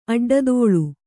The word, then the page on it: ಅಡ್ಡದೊಲೆ: aḍḍadole